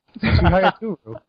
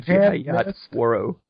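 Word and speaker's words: Chihayafuru: Chihaya Turu. – Oh, I was pronouncing Chihaya Tsuro.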